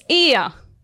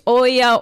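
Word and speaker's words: Oia: Or, like, we have one place very popular in Santorini, and the Greek name is Ia. It's one place, but because it's right, O-E-A, and that we pronounce it Greek E, many people they say, where is Oya, Oya, Oya?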